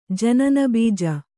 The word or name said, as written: ಜನನಬೀಜ